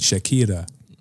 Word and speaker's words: Shakira: That's how you say it, Shakira.